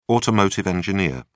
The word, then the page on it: automotive engineer: British English